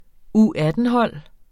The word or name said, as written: U18-hold